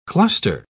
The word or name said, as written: cluster